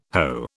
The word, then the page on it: Ho: British English